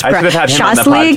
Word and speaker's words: shashlik: It's like shash. Oh God, I am married to a Ukrainian. Like, I should say this. – Shashlik. – Like, it's shashlik.